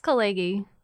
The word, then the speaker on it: Khaleghi: It's Khaleghi.